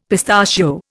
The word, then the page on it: pistachio: Indian